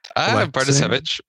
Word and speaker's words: Bartosiewicz: Bartosiewicz.